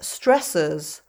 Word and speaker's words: stressors: So I'm afraid that word that ends O-R-S in my idiolect is pronounced stressors, which is very confusing because it sounds like the plural of the word stress, as in stresses and strains.